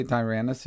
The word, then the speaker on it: Tyrannus: Yeah, I would say Tyrannus.